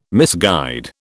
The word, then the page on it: misguide: British English